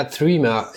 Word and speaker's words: Threema: Gut, also äh, was ist denn überhaupt äh, Threema, Trema? Wie spricht man es eigentlich richtig aus? – Ja, Threema. Ja, Threema ist, ist, steht für 3, also 3 in Englisch und MA für Message Application und das 3,